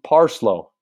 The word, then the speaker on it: Parslow: Mr. Nick Parlson. Oh, just, no, not Parlson, Parslow. Is that how you say it? It's got to be P-A-R-S-L-O-W.